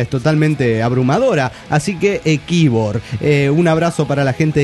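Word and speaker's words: Quíbor: es toponomía indígena venezolana. – Bueno, pero suena. – Y se pronuncia como se escribe, Kibor.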